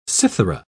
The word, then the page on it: cithara: British English